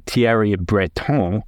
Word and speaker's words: Thierry Breton: EU Internal Market Commissioner Thierry Breton, and yes, I pronounced it Thierry Breton because I'm fancy like that,